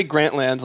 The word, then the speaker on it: Grantland: You know, it's named after Grantland Rice, the writer, so you say it like that. – Grantland. – But if you want to say Grantland, like it's some kind of theme park, please be my guest.